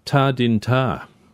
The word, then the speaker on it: Ta Din Ta: I'm not sure if I'm going to pronounce this correctly. Ta Din Ta. – Perfect. Well done.